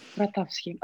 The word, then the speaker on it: Grotowski: Grotowski.